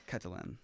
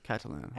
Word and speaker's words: Catalan: Catalan. – Catalan. – And you're like fluent in all of those? – No. I'm fluent in English, Spanish, mostly Farsi, and I'm like least fluent in Catalan.